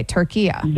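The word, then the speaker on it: Türkiye: And so now the instruction is that also in English, say it like how it's essentially supposed Turkiya. – To be pronounced. Okay, Turkiya. – There you go. That's perfect. That's how it's pronounced. It's always been pronounced that in Arabic language.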